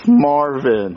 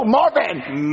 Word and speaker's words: Marvin: It's Marvin. – Oh, Martin. – No, Marvin! – Martin. – No, Marvin, I'll spell it.